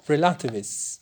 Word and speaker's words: relativist: Another very practical consequence is that we have to stop being relativists. We have to stop being relativists. The accent on the first E, relativist.